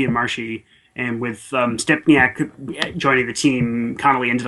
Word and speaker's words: Stempniak: Step what's how do you say his last name? Stempniak. Stempniak.